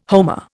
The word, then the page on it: homer: Indian